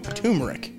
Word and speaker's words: turmeric: Turmeric. – I see, but it's got an R in there. T-U-R.